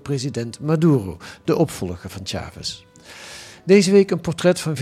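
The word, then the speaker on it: Chávez: In 1998 werden die gewonnen door Hugo Chavez, een man, zeg je dat hij goed, Chavez? – Chavez. – Ja, maar de klemtoon wel op A, hè? Ja. Chavez.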